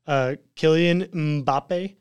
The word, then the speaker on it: Kylian Mbappe: Kylian Mbappe.